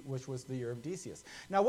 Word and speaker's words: Decius: It's Decius, is how we, we call this guy.